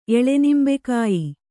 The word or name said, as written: ಎಳೆನಿಂಬೆಕಾಯಿ